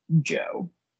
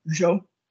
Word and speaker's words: Zhou: If you watch the broadcast, they call him Joe. – Okay. – But I think it's pronounced more like Zhou.